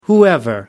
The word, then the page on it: whoever: American English